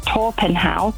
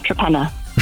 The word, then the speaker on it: Torpenhow: I actually got married in a place that you would pronounce Torpenhow, because that's how it's spelt, but it's actually called Trepenna.